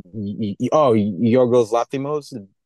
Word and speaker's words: Yorgos Lanthimos: Yorgos Lanthimos, did I say that right? – Yeah, I think you did.